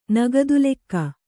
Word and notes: ನಗದಿಲೆಕ್ಕ: nagadi lekka; kannada

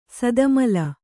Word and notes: ಸದಮಲ: sadamala

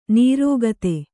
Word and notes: ನೀರೋಗತೆ: nīrōgate

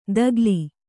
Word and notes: ತಕಲಿ: kannada

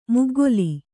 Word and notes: ಮುಗ್ಗಲು: muggalu

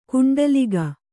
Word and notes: ಕುಂಟಲಿಗ: kuṇṭaliga

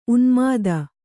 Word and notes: ಉನ್ಮೋದ: kannada

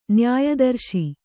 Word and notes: ನ್ಯಾಯದರ್ಶಿ: nyāya darśi

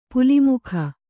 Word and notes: ಪಲ್ಮಿಗ: Kannada

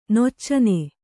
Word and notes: ನೊಚ್ಚನೆ: noccane

